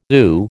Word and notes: ooh: American English

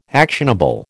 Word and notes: actionable: American English

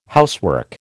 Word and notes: housework: American English

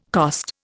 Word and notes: cost: Indian

